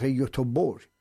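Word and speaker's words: Göteborg: گی که آخر مثل شهر یوتوبور.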